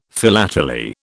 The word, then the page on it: philately: British English